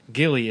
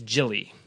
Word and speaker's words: Gilly: So it's like instead of Gilly, it's Jilly.